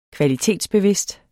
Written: kvalitetsbevidst

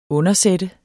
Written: undersætte

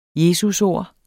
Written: jesusord

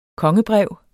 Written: kongebrev

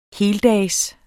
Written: heldags

